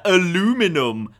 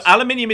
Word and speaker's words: aluminium: I thought aluminum was the original pronunciation, and then we changed it. – I think it was. No, aluminium is the actual element. Aluminium is just an accepted North American variant.